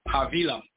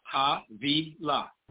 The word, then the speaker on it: Havilah: Now we got the firstborn sons of Ham, Cush, Seba, Hawilah. Always correct me if I'm saying it wrong. – Havilah. – Say it again. – Havilah. – Oh, it's a V sound.